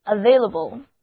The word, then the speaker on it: available: 接下来让我们在另外一个词根 VAIL 他的身上做文章，首先在它的前面加上表示加强意义的字母 A，后面再跟上 able 这个形容词后缀，于是三部分合在一起 available available。除了中间的字母 A 读它的本音外，前后的两个字母 A 都读作短的 a。